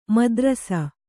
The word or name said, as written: ಮುದ್ರಸ